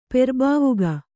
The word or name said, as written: ಪೆರ್ಬಾವುಗ